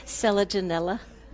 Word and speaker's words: selaginella: It's a selaginella, which is a ground cover. But I'm glad you pronounced it because I always botch it. – Selaginella.